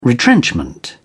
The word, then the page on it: retrenchment: British English